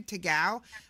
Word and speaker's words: Tagow: So his name on YouTube is Take a Glass of Water, T-A-G-O-W, Tagao. But I call him Take, T-A-K-E. – T-A-K-E.